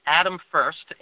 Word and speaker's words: Adam First: If you'd like to reach me, my email address is my name, Adam First, A-D-A-M dot, and the last name is spelled a little different.